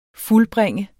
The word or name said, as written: fuldbringe